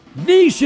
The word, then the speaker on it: niche: Niche is correct. – Yeah. – Not niche.